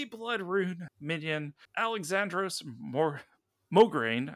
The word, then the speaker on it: Mograine: I can't say that. say that correctly. I want to say that there's an R in there. – Morgraine, right? Like I want to say it the wrong way.